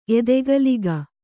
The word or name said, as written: ಎದೆಗಲಿಗ